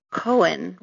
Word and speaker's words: Cohen: It is a very common name, but unlike some others that are C-O-H-N, my name is C-O-H-E-N, thus giving the pronunciation a little schwa difference of Cohen rather than Cone.